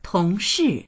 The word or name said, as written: tong2shi4